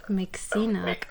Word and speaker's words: Mixinook: Yeah, I mean, it's more like Sinok, like a long E. – But Mick is correct. – I mean, it's just nonsense, so. – Okay, I was. – That was my. It's not like a real anything. – Okay. – But how it's pronounced, I guess, is Mixinook.